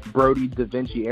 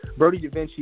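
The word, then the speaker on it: BrodieDaVinci: My Facebook name is Christian Brody Da Vinci Erickson. Brody Da Vinci is spelled with a capital B, then R-O-D-I-E, then a capital D, then a lowercase a, capital V, then I-N-C-I.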